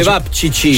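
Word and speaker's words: ćevapčići: Cevapcepi, com'è che si pronuncia? – Cevapcici. – Cevapcici, ok, cevapcici.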